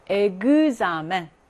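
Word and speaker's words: examen: 으엑스는 으엑스 플러스 모음이 나오게 되면 그즈 발음입니다. 그즈. 그래서 에그자멘, 에그정플르. 이런 식으로 그즈 발음이 나요.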